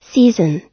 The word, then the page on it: season: American English